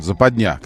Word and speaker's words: западня: Запиндя такая. Западня, как правильно говорить.